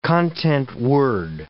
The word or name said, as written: content word